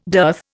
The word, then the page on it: dearth: Indian